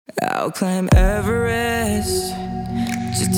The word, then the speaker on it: Everest: I would climb Everest.